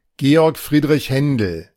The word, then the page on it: Georg Friedrich Händel: ˈɡeːɔʁk ˈfʁiːdʁɪç ˈhɛndl̩